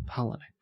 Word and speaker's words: Palahniuk: Palahniuk. Yeah, Chuck Palahniuk. – What do you do with the U? There's a U in there. – You just pretend it's not there. – Palahniuk.